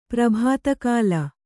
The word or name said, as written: ಪ್ರಭಾತಕಾಲ